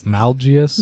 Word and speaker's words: Malgus: Yeah, it's like, And it was so interesting to see Darth Malgus come in. – Mm-hmm. – I think I'm pronouncing his name right. – Darth Malgius, yes. – Malgius, sorry.